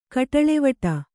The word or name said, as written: ಕೋಟಲೆವಡು